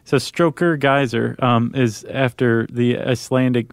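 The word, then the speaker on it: Geysir: Geyser.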